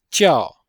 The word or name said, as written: jiao4